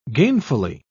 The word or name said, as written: gainfully